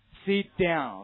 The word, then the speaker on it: sit down: OK，我们经常见到呢，比如说啊，您非常熟悉的任何一个中学老师，如果让你起来回答问题，然后回答完了以后，他说让你坐下啊，即使水平语音水平再差，老师也不会说什么 sit down， OK 啊，不符合读音规则，怎么读的？ sit down， OK， sit down，这就是非常典型的失去爆破的现象， sit down。